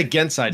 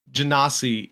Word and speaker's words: Genasi: feel like yeah, I feel like uh, there's probably multiple ways to pronounce it, but it I believe Genasi is the is the correct pronunciation.